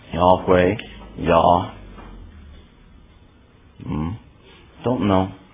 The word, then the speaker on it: Yahweh: and that they are convinced that the proper pronunciation of the Tetragrammaton is Yahweh.